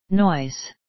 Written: noise

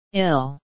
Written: ill

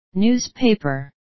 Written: newspaper

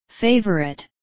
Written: favourite

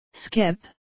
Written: skip

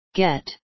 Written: get

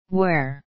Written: where

